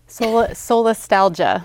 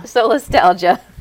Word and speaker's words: Solastalgia: Solastalgia. – Solastalgia.